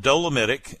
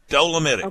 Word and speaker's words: dolomitic: You just tell them you want dolomitic, and now you know how to say it. You can say it out loud proudly. Dolomitic.